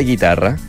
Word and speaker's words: chitarra: Se escribe chitarra, pero me dijeron que se pronuncia guitarra.